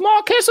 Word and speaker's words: Marquess: Henry William Paget, first Marcus. Oh, I'm pronouncing everything wrong. This is why I didn't want to do this. Sorry, I'll fix it. One famous ghost is Henry William Paget, first Marquess of Anglesey.